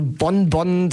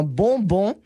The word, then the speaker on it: bonbon: Also Bonbon, das ist schon richtig falsch ausgesprochen. Du musst es perfekt français aussprechen und zwar Bonbon.